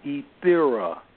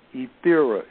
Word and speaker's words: Ethera: The E in my name stands for Ethera. I'll pronounce it again, Ethera. It's like the word ether with an A on the end, Ethera.